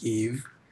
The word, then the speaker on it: Yves: I'm an artist, producer, creative director, and founder of my own streetwear line called Yves Streetwear, written as YV, but you like pronounce it, so it's Yves.